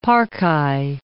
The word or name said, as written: Parcae